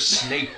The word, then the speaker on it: Snape: No, she's talking about Professor Snape. You're saying it wrong.